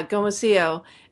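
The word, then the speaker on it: gomasio: and is it pronounced gomasillo? Is that how you pronounce it? – Yeah, gomasillo.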